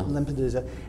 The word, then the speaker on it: Lampedusa: Lampedusa.